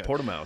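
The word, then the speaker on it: Portimão: The Algarve, okay, Portimao.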